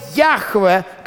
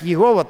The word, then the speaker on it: Яхве: Слово «Яхве», которое не очень правильно переводится в русском синодальном переводе как «Егова», так не произносится, конечно.